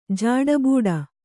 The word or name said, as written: ಜಾಡಬೂಡ